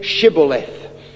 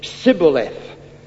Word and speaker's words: Shibboleth: When they said no, they said to him, then say Shibboleth. And he said, Sibboleth. For he could not pronounce it.